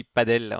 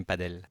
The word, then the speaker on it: padel: et là on dit padel, on essaye de le prononcer à l'espagnol, même si effectivement à l'origine les origines étaient proches, et donc c'est pour ça qu'il peut y avoir une confusion, mais voilà, on dit bien padel.